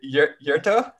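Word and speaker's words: Yetur: Is it Yurto? – No. You actually did it right, but I could tell it was a struggle.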